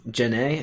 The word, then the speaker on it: Jhené: I pronounce Jhené. It has an accent over it.